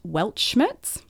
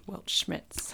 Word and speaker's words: Weltschmerz: It's called Weltschmerz. – Weltschmerz.